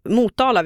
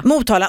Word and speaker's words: Motala: Motala, och det, det värsta är att jag är ju från min, släkt från Motala. – Mm. Man säger Motala. Nej. – Motala. Nej, Motala. Motala.